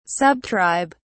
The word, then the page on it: subtribe: British English